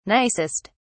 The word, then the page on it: nicest: British English